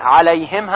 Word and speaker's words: عليهم: توجه دارید که عليهم هم باید با عین تلفظ بشه، کما اینکه انعمت هم با عین تلفظ میشه.